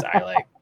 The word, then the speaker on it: Islay: Islay? I don't know which way is the actual pronunciation. I just know there is a wrong way to say it. It's Islay.